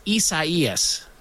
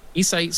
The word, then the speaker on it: Isaias: It's spelled almost like, it's almost spelled, it's spelled similar to Isaiah, but it's pronounced Isaias.